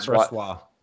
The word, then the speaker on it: Brisbois: That kid Brisbois, I think it was. Is that Brassois. How you pronounce it? – Laurent Brisbois.